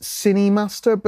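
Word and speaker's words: KineMaster: And, you know, actually, there's a, I've mentioned this before, but there's a, I think it's pronounced Cinemaster, but it's spelt with a K, which is a editing app, certainly for Android.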